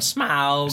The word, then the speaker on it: Smaug: Desolation of Smaug. – Smaug. – My favorite part of that is listening to people as they try and pronounce Smaug. – Smaug. Yeah. – Is everything from smog to Smaug.